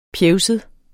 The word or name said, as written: pjevset